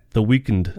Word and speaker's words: The Weeknd: The Weeknd. – Weeknd. – Oh, Weeknd. Weeknd? – Weeknd. Yeah. – But it's pronounced The Weeknd.